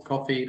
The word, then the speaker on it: Ko-fi: That might be not quite the right term, but we have a donation platform on Coffee, that's spelled KO-FI, where you're able to donate to the company either through subscriptions or one-time donations.